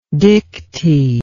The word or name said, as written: dickty